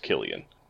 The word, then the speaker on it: Cillian: it's actually properly pronounced Killian. – Don't pronounce it like me. Don't do that. – it's spelled Cillian, but it's pronounced Killian.